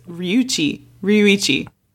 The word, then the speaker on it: Ryuichi: And this boy, the older boy, is Ryuichi. There's an extra syllable in there.